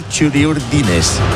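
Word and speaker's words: txuri-urdin: Esperamos a que por la Bocana aparezcan los futbolistas Churi Urdines. No, Churi Urdin. No se dice Churi Urdines, se dice Churi Urdin.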